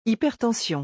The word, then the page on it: hypertension: French